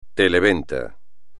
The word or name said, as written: televenta